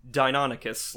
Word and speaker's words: Deinonychus: You pronounce it because it's Dino, like the Pokemon, yeah. And then I'm pretty sure it's Deinonychus. Like it's I think that's a real kind of dinosaur.